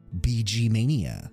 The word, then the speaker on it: BGMania: So from that, we have the great BG Mania baking show.